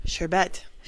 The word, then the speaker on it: sherbet: And a lot of people say sherbet, and it's sherbet. – Sherbet. Your bet. It's a sure bet.